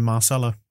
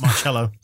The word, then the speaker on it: Marcelo: I've just realised very, very late, but you should be our hero of the week, Moscow, because you're finally saying Marcelo and not Marcello.